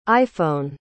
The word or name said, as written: iphone